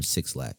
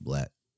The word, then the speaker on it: 6lack: Black, or as some people may call him, Six Lack. It's pronounced Black.